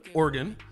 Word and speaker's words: Oregon: And Portland, Oregon. Am I saying that right? – Yeah, Oregon.